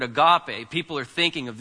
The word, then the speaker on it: agape: In the Bible, you have this word agape, which many people mispronounce as agape, but that's fine. It's agape, because the accent's on the end.